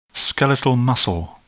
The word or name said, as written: skeletal muscle